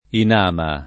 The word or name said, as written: Inama